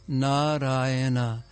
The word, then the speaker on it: Narayana: Those four syllables, Narayana, the name of Krishna.